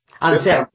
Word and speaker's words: Anserma: Creo que yo estaba pronunciando Anserma, dije Anserma. – Anserma, sí, con R.